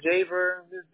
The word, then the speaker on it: Jaber: Was it Jobber or Jabber? – Jobber, Jaber. Jaber.